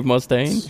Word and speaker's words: Mustaine: His name Dave Mustaine. – Yeah. Which episode was this? Back in obscure 80s. – Yeah, it was obscure 80s days. Yeah. Hmm. So. – I think I remember that episode. – Mustaine.